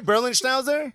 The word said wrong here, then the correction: Berliner Schnauzer: Berliner Schnauzer. – Berliner Schnauzer.